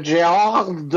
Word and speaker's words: Gerard: I've seen a disaster movie this week, Luke, with Gerard. Because that's how you pronounce his first name.